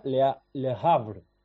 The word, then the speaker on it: Le Havre: Creo que se pronuncia Le Havre.